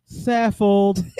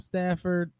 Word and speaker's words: Saffold: Well, our player highlight this week is going to be Roger Saffold. – Because I say it wrong all the time, so. – You call him Stafford.